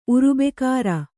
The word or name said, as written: ಉರುಬೆಕಾರ